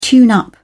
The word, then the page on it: tune up: British English